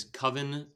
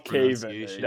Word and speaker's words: Coven: Last bit of Marvel news is Coven of Chaos. Am I saying it right this time? – Yeah, the correct not Caven. Definitely not Caven.